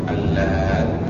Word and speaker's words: Allat: Pengejaannya bukan lantah tapi Allah. Alif lam lam alif ta ta ya musyaddad. Jadi kita kata latta yaluttu allat.